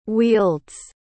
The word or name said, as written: wields